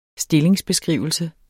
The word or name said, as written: STILLINGSBESKRIVELSE